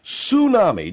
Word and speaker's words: tsunami: I know it starts with a T, bud, but it's T-S, Tsunami. Just don't pronounce the T. Just a silent T.